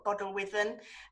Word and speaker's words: Bodelwyddan: did um, i think we did a couple of sets when i say sets there were probably about 50 um, for um, I probably pronounce it wrong, but Bodelwyddan uh, Hospital. – Sounds right uh, to me.